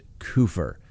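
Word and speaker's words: Kupfer: The name is spelled K-U-P-F-E-R. I watched four pronunciations of the name, but the news organization that interviewed the family pronounced it Kufer. So I believe they're the best way to make sure we get the pronunciation correct.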